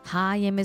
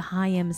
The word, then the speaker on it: Haim: Haim is playing a tour sponsored by a credit card company where they go to a bunch of delis.